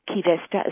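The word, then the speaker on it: Qi Vesta: Key Vesta is spelled Q-I and then V-E-S-T-A.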